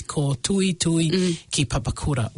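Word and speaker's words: Kootuitui ki Papakura: So let me spell it again. It's Koh Tui Tui Ki Papakura. K-O-O-T-U-I-T-U-I-K-I. Papakura, that's my Tongan when I say it, tuhi tuhi, but it's Koh Tui Tui Ki Papakura, weaving together education, health and homes.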